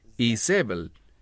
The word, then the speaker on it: Izebel: Ahora, el término Jezabel, esa es la pronunciación en español. En hebreo es Izebel.